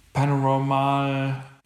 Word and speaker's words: Paranormal Activity: Und ich habe aber auch, weil ich einen sehr, sehr guten Film fand, war damals äh, hier dieses Paranormal Activity-Ding.